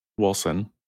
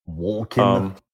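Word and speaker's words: Wolcen: Wilson. – Think Wolfen, but with a soft C. – Wolken. – Wilson. – No, soft C.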